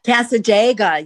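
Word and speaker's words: Cassadaga: Now you have workshops in, and I hope I say this right, but I've been there, Casa Casadega? – Casadega, yeah.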